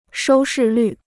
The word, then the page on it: 收视率: shōu shì lǜ